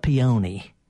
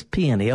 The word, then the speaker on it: peony: And folks in Cleveland, they call it peony. – Peony. – But the Peony Society says peony.